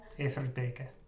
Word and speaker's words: Everbeke: Well, it depends if you're pronouncing it in Flemish or in French. – How is it in French? – I assume it would be like Everbeek, because they spell it like E-V-E-R-B-E-C-Q. In French. – Okay. – And in Flemish, it's E-V-E-R-B-E-K-E.